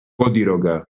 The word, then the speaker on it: Bodiroga: How do you say that in like English? – One more time. – So it's Dejan, D-E-J-A-N, and then B-O-D-I-R-O-G-A. – Okay. – O-D-I-R-O-G-A. Bodiroga? – Bodiroga. – Bodiroga. Okay.